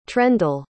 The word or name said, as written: trendle